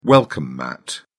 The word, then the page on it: welcome mat: British English